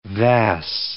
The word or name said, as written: vas